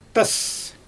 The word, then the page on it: tas: Xyzyl